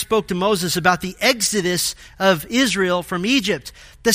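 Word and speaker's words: exodus (ἔξοδος): The Greek word for departure here is pronounced exodus, and is speaking of his coming death.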